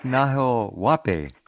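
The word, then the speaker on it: Nahuel Huapi: That's Lake Nahuapi is actually how you pronounce it.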